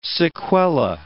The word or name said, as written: sequela